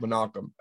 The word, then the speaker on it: Menachem: Mannheim Globus? No, that's not it. – The one that looks like Mannheim is actually pronounced Menachem.